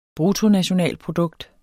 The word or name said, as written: bruttonationalprodukt